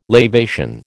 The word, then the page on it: lavation: American English